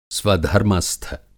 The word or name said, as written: स्वधर्मस्थ